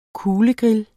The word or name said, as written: kuglegrill